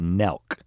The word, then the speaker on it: Nelk: Um, but because I have a teenage son, I know about Nelk, N E L K, rhymes with Belk, and these guys.